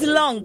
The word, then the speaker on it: Long: So it's Long. Listen, long. Whole time I say Longkey. Yeah, okay. I keep saying Longkey.